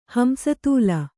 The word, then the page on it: ಹಂಸತೂಲ: Kannada